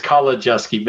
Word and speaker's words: Kolodziejski: It's Kolodziejski, but thank you, Bonnie.